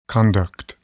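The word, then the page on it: conduct: noun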